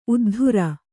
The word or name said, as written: ಉದ್ದರಿ